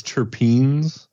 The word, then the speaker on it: terpenes: The lemon haze terpenes. – Yeah, are very popular with the kids, the terpenes. – Yeah, the terpenes. Wait, hopefully I'm not mispronouncing that. Terpines? Is it terpenes? – Terpenes.